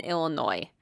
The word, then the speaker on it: Illinois: So, the place that I'm covering today is called Mick Pike Mansion at Alton, Illinois. Cool. And even though there is an S on the end, we do not pronounce it.